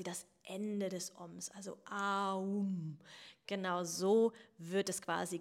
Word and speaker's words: Om: Und tatsächlich wird das Om auch eigentlich Aum ausgesprochen. Also quasi dieses A, wir machen den Mund auf, wir beginnen, das Universum beginnt, wir beginnen unser Leben mit einem A, mit einem Schrei.